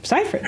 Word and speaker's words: Seyfried: Seyfried. Her name really? Rhymes with rye bread.